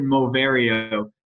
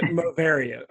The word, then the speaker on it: Moverio: It's the Epson Moverio smart glasses. – There you go. Moverio.